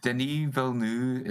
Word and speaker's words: Denis Villeneuve: Denis Villeneuve is how I heard it's supposed to be oh, pronounced.